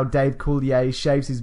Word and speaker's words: Coulier: Where he talks about Dave Coulier. Is that how you say it? – Dave Coulier.